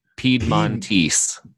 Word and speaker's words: Piedmontese: Now, are you guys the only one, like, how, what is the Montrese? Is it what I'm saying that right? Montrese? – Piedmontese. – Piedmontese.